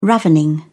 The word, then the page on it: ravening: British English